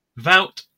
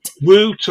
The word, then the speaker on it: Wout: They're going Woot or Voot, but that's not how you pronounce it.